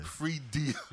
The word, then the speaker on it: Freedia: The I is silent. – It's Freedia. – Freedia.